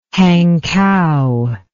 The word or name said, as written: Hankou